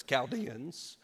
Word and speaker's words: Chaldeans: that's how you say it, by the way. You, you want to say Chaldeans, but it's Chaldeans.